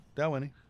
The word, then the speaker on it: Dalwhinnie: And am I saying that right, by the way? – As far as I know, yes. – Yeah, how would you say it? Dalwhinnie? – Dalwhinnie.